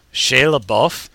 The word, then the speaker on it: LaBeouf: Shia LaBeouf? – Because it's Americanized French, and that's just how the actor says it, or, well, all the entertainment stuff about the actor. Shia LaBeouf.